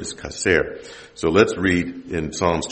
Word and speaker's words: chacer: The transliterated word for lack or want, because it's the same word, is kaser. It's spelled C-H-A-C-E-R. And it means to have no need,